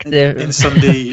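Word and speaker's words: Incendies: Denis Villeneuve, who famously did Blade Runner 2049, Prisoners, and a film that you always tell me to watch, which I will, which is Incendiaries or Incendi? – Incendi, I think. – Incendi, French. Okay, so is it French? – The French pronunciation is, yeah.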